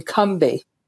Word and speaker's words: Combahee: And again, a little fun fact is that we've been pronouncing it incorrectly all these decades. It's actually Cumbie, as if it's two syllables with no A in the middle.